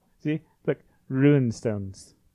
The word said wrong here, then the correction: runestones: No, it's runestones.